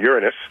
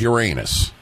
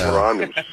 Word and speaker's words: Uranus: It comes from the Greek. You look at the Greek letters that spell it as Ouranus, And there's no way you get Uranus out of that. It's Uranus.